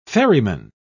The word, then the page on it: ferryman: British English